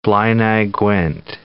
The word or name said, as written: Blaenau Gwent